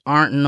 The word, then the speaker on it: Ironton: Well, Omope was born and raised in the River City of Ironton, Ohio, more correctly pronounced in the proper dialect, Arnton, Ohio.